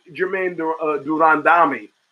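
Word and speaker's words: Germaine de Randamie: Now, Amanda Nunes and Jermaine Durandami, as she's instructed me to pronounce it, I had to pick her.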